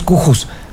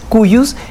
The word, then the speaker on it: cujus: Se escribe cujus. ¿Y tú qué es eso? Un día una clienta me dijo, mi papá no es cujus. Ya sé que no es cujus, es cuyus y es de cuyus. De cuyos, se pronuncia cuyos, que es de cuya sucesión se trata.